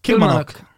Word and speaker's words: Kilmarnock: Är det, är det Kilmarnock eller Kilmarnock? Kilmarnock. Jag gillade den första bäst. – Kilmanock. – Kilmanock. – Ja, vi slår fast Kilmarnock.